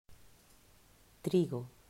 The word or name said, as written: Trigo